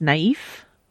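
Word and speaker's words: naïve: Number one, the word is pronounced naive, like naive, but with a sound, so naive. – Okay. – Because there's like an umlaut over the I.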